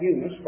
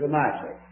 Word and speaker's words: Eunice: And I won't call that Eunice, but I'll show you in my Bible the old English pronunciation is Eunice. Back when I was young, first time I ever heard a preacher pronounce it that way, I thought, that poor old, old man. I checked it out, it's Eunice.